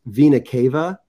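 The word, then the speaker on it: Vena cava: But so Venikava, what Venikava is, and it's actually pronounced Venikava.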